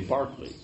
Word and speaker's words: Berkeley: Like I just found out that Berkeley, you say Barkley, right?